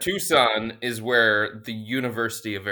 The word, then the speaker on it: Tucson: Dave and Tuxin ask. – Hold on. Did you just say Tuxin? – Yeah, what? It's T-U-C-S-O-A-N. – It's Tucson. Tucson, Arizona.